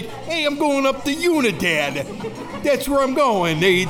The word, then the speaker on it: Unidad: It's Unidad, which means unity in Spanish. – Unidad. So I will stop calling it Unidad.